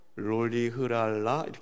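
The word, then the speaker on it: Rolihlahla: 미들네임은요 제가 아무리 발음을 해도 저걸 어떻게 읽어야 될지 읽기가 어렵더라고요. 넬슨 롤리흐랄라 이렇게 해요.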